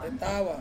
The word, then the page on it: tawa: Ansus